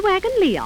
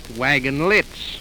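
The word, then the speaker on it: Wagons-Lits: This is the Vagonlis office. – Why, it's spelt Vagonlits. – I know it's spelt Lits, but over here they pronounce it Lee.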